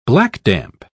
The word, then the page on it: blackdamp: British English